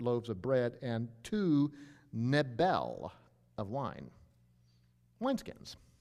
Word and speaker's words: Nebel: It's pronounced Nebel.